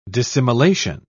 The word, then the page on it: dissimilation: British English